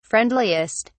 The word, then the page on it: friendliest: British English